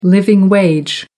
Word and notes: living wage: American English

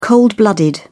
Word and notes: cold blooded: British English

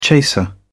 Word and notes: chaser: British English

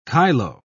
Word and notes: kyloe: British English

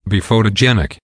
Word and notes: be photogenic: American English

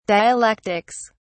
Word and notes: dialectics: British English

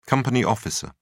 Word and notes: company officer: British English

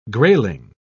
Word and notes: grayling: British English

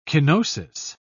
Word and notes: kenosis: British English

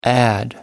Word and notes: add-in: American English

